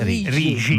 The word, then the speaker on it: Rixi: Certo, vabbè, noi comunichiamo al mondo che Rixi si legge Rigi.